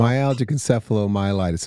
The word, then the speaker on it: Myalgic encephalomyelitis: Myalgic encephalomyelitis.